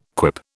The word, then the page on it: quip: British English